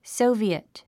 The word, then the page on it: soviet: sóuvièt; ソビエト